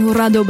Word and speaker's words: Jurado: Damien Jurado. – Well, you said it right. – Damien Jurado bei Flux FM, denn schließlich ist sein Vater Mexikaner. Und da muss man das auch richtig aussprechen.